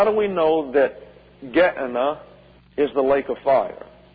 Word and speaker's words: Gehenna: and the sentence of Gehenna or Gehenna. Let's take a look at this. Gehenna is the Greek pronunciation. Gehenna is the anglicized version.